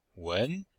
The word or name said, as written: wen2